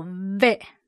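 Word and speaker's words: ve (וְ): 这里牵涉到希伯来文的一个很特殊的文法，希伯来文的连接词和，一般这个字单独我们念作 ve，这个 ve 是一个上齿咬下唇发出的音 ve。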